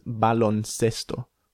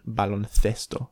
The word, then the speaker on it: baloncesto: This is baloncesto. Or if you're in Spain, baloncesto, lisping the C sound.